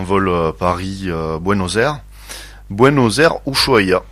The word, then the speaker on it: Ushuaia: Alors, Ushuaia, vous le prononcez très bien, c'est Ushuaia en réalité, hein, comme prononcent les locaux.